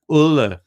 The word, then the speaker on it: Ole: Ole.